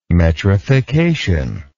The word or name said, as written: metrification